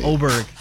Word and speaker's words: Åberg: But, yeah, Åberg, is that how you say it? – Yes, that is how you say it. – Åberg.